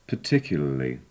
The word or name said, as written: particularly